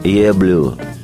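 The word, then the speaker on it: Yer Blues: Со второго диска белого альбома «Битлз» тоже предложу две песни, и одна из них – вещь, ставшая наиболее болезненной исповедью Леннона Джона «Еблю». Название это означает не больше, не меньше «твой блюз». Это типичное ливерпульское произношение не «your blues», а именно «year blues» сленговое произношение.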